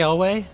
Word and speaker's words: Elwes: Cary Elwes? – Yeah.